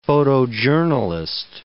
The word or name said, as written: photojournalist